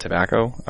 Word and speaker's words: Tabacco: The quick rundown on me is my name is Matt Tobacco. That is my name. It is spelled T-A-B-A-C-C-O.